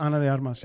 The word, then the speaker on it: Ana de Armas: Probably mispronounced her name, so I apologize. – No, it's Ana de Armas.